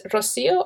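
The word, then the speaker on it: Rocío: And my name is Rocio, R-O-C-I-O. And the last name is spelled Baeza, B-A-E-Z-A.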